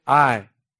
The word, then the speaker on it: Ai: It's A-I, Ai. If you want to be really intense, you can say Aia, but that's too annoying, so we'll just say Ai.